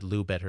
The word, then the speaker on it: Loubet: It's L-O-U-B-E-T. It's French. The T is usually silent, but since he's American, for all I know, he pronounces it Loubet or something.